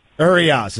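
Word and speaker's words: Urías: Urias, is that the Dodgers pitcher? Did I pronounce it correctly, Tim? – Yes, he's pitching tonight, Stugatz.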